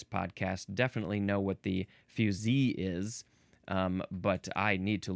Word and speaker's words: fusee: This is spelled F-U-S-E-E, pronounced fusee.